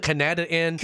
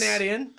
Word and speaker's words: Canad Inn: Because we kept calling it the Canada Inn. – Canadian Inn. – Isn't it the Canad? – Is that what it is? – Canad Inn. – I thought it was the Canad. The Canad Inn.